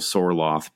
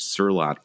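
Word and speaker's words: Sørloth: I think it is, and I love how we have morphed in this segment from our traditional Sorloth pronunciation to the correct Surlot pronunciation.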